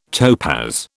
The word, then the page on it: topaz: British English